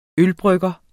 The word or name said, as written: ølbrygger